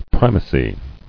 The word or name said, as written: Primacy